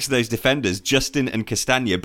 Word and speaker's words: Castagna: Castagna? – Yeah, Castagna.